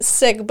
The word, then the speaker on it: Sikh: Okay, so you said sick, but you mean like the religious. – Yes. – How do you spell it? Isn't it like an interesting spelling? – S-I-K-H. – Okay, that's what, right. So people just don't have in their mind like coughing sick.